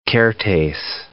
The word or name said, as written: Kertesz